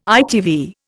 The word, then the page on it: ITV: Indian